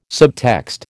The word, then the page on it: subtext: American English